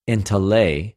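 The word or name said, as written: ἐντολή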